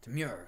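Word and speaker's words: Teymur: I think it's Termuer. Temur, that sounds right.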